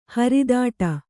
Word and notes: ಹರಿದಾಟ: haridāṭa

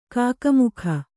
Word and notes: ಕಕಮಕ: kakamaka

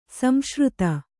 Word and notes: ಸಂಸೃತ: samsřta

